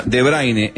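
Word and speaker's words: De Bruyne: Dice, se pronuncia De Braine en Dutch.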